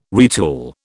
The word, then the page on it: retool: British English